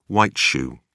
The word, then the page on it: white-shoe: British English